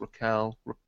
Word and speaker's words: Raquel: It looks like Rachel, but it's Raquel.